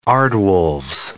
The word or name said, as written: aardwolves